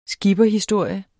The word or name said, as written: SKIPPERHISTORIEN